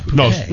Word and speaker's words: Phuket: It's Phuket. You pronounce the T.